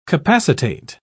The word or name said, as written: capacitate